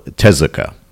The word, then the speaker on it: Tezuka: The 2019 Dororo anime series is based on the manga of the same name by Osamu Tezuka. I said it right this time.